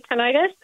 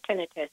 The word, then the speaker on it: tinnitus: Well, people say tinnitus, or they might say tinnitus. You can say either or. It's fine. It just depends on how you choose to pronounce it. But either of them is fine to say.